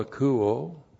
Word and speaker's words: akouo: A-K-O-U, long O, akouo.